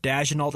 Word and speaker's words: Daigneault: And Mark Daigneault, I think is how you say his name, their head coach from Oklahoma City.